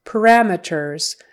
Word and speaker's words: parameters: Parameters. Syllable stress is on the second one.